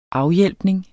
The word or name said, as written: afhjælpning